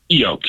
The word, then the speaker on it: Keyo: Keyo, K's like a key with an O on it.